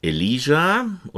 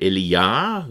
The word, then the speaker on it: Elia: Elijah oder Elia, wie er eigentlich richtig ausgesprochen wird, ist ein Tischbitter, also ein Lohnabhängiger, der keinen Grundbesitz hat, also jemand, der für Geld für andere arbeitet.